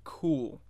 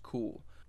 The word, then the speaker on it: Cuil: But apparently, it's pronounced Cool. It's spelled C-U-I-L. You know, when I first looked at it, I had no idea how it was pronounced. Apparently, it's pronounced Cool.